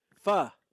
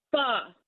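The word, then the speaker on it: phở: Pho. – Well, you actually pronounce that pho.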